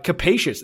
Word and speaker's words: capacious: Let me see how to say this. Capacious. That is exactly how you pronounce it, by the way.